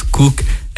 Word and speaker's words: Koch: C'est Merrill Cook, K-O-C-H, mais lui, il prononce Cook.